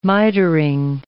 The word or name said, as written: mitring